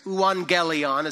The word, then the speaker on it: euangelion: It's euangelion is how you say it, with the little accents and things.